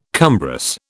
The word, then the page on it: cumbrous: British English